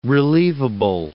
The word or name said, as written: relievable